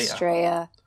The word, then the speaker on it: Estrella: Estrella. – With the double L in Spanish. – Yes. – Pronounces a Y.